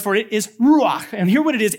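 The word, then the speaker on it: ruach (רוח): When it comes to the word spirit, the Hebrew word for spirit is ruach. Yeah, I'm not trying to hawk a loogie. It's just, it's how you say it, okay?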